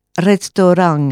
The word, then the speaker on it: restaurang: Mais vous avez aussi restaurang. Je ne sais pas si vous avez entendu à la fin du mot, j'ai dit ang.